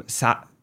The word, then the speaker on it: sauna: but up here we pronounce him sauna or something crazy sauna.